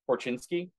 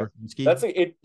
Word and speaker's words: Korchinski: Another signing that happened: first round pick, 7th overall, Kevin Korchinski. – That's right. – Did I say that right? – Korchinski. Yeah. Korchinski. That's a great Chicago name.